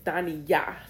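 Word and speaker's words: Daniyah: And it is the part, it's the emphasis on the right syllable. The emphasis is on Yah. It's Dani Yah. That is how you say it.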